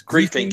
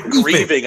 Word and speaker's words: griefing: Is that the correct way to say it? It's griefing. Yes. Okay. Said grieving. I was sorry.